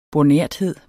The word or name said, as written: bornerthed